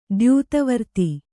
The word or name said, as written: ದ್ಯೂತವರ್ತಿ